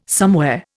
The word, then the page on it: somewhere: Indian